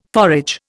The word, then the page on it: forage: Indian